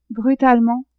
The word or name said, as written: brutalement